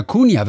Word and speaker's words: Cunha: Oder Cunha wird es, glaube ich, ausgesprochen. C-U-N-H-A, so wird es auch gesprochen. Tristan de Cunha.